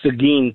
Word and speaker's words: Seguin: It's actually called Seguin, Texas.